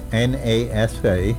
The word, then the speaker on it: NASA: He would like to know how do you pronounce NASA? – Yeah. It's NASA. Uh-huh. National Aeronautics and Space Administration. And it's NASA.